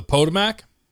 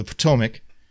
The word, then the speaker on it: Potomac: Oh, the Potomac? – Yeah, the Potomac in between.